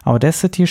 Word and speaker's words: Audacity: Audacity spreche übrigens so aus, wie man es schreibt. Äh, die englische Aussprache klingt für mich nach einem viel besseren Programm, als es tatsächlich ist.